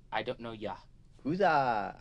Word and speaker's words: Yakuza: The Yakuza. – Yakuza. – Yakuza. – Yakuza. You pronounce mm-hmm. the A more.